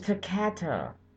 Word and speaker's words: Takata: Thank you, Takata. Or Takata. Oh! I've been saying it wrong this whole time! It's Takata.